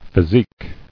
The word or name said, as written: Physique